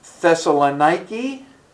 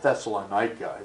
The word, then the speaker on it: Thessalonica: And so it's kind of fun when our tour guides are talking and you hear, it's not Thessalonica, it's Thessalonica. And that's the way they'll pronounce it.